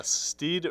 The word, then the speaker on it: Steed: Yeah, Steed rhymes with speed, so maybe lay off the speed, Steed.